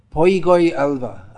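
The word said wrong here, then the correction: Poigai Alvar: I probably didn't say that correctly. Poigai Alva. Poigai Alva.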